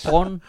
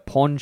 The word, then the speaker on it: porn: So at first, when you said porn, I thought you meant like a pawn shop. You got to pronounce the R, bro. Porn.